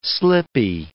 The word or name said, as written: slippy